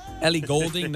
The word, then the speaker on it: Ellie Goulding: Ellie Goulding? No, she's not a country singer. – Eli Goulding. – Eli Goulding. – I mean, just pronounce the name right.